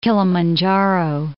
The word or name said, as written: Kilimanjaro